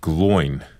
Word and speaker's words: Gloin: Yeah, because you were Gloin in The Hobbit. Did I pronounce that right? – You did.